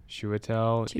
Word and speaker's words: Chiwetel: Shishwichel? It's a hard name to say. – Chiwetel Ejiofor?